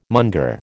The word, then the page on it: monger: American English